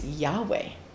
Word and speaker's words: Yahweh: But actually, it's Yahweh. We don't know exactly how to pronounce it, but we know pretty sure it's Yahweh or something along those lines.